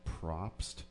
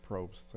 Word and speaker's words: Probst: The Jeff Probst show? Probst, I think. – Oh, Probst.